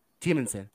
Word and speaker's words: Temanson: Temanson.